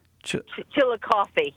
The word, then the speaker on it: Chillicothe: If we can spell Chillicothe, did I say it right? Chillicothe? – Chillicothe, yes.